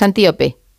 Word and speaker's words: Antíope: ahondas mucho en la relación de Teseo con Antílope. Pero lo he dicho mal el nombre, es Antíope.